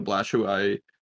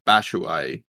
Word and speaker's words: Batshuayi: They got rid of Batshuayi to, I think I just said that wrong, Batshuayi to Besiktas, on loan.